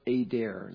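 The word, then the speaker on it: Eder: But it mentions in Micah 4 and then Micah 5, the Tower of Adar is the correct pronunciation.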